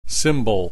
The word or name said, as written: symbol